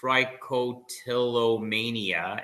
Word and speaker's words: trichotillomania: Would you also help people who suffer from trichotillomania and other picking disorders? – Yeah.